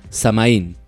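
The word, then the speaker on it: Samhain: Estos satanistas alababan y servían al dios de la muerte que se llamaba Samaín. – Sí. – Pronunciado Sama. – Sama. – Sama, algo así.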